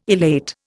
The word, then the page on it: elate: Indian